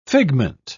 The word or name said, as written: figment